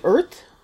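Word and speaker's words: Urðr: So I am still working on the, and again, this is a rough pronunciation, the Earth shawl. It's U-R-D-R, but the D is the sort of Norse D with the curvy ascender and the slash through it,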